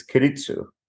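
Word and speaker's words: Keiretsu: You share the story of a forgotten Japanese business model known as Kiritsu.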